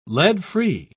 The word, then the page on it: lead-free: British English